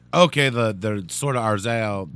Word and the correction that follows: Azrael: It's Azrael, dude. Who's Arzael? – Whatever, dude.